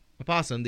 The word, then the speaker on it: opossum: And the opossum, the Didelphis virginianus, O is supposed to be pronounced. There is a different creature that has known as the possum that doesn't have an O.